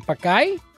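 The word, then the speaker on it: pacay: Okay, so I'm going to probably butcher this one. Pakai? – No, you actually said it right.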